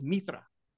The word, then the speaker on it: Mirtha: It's spelled like when you look at it on the subtitles, it's M I R T H, but the way they say it is Mitra.